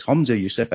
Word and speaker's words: Humza Yousaf: Humza Yousaf, again, I mispronounced just like Ross Foyer did, so it's Humza Yousaf, and he was very nice about that.